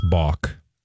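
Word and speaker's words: bawk: Is it buck? – No, it's balk. – Yeah. – The correct pronunciation would, of the chicken listen, noise is balk.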